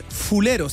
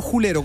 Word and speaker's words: fulero: La palabra es fulero, se escribe, no julero con J. – Claro, es que julero es más flight. – Claro, uno lo pronuncia como julero, pero en realidad lo correro es fulero.